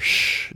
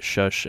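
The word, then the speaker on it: Shhh: It was originally intended to be pronounced with no vowels, but then people just naturally put the in there to make it Shush, and I don't care as long as it's spelled with an S and three H's.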